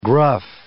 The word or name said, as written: gruff